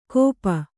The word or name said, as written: ಗೋಪ